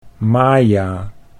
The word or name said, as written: Maja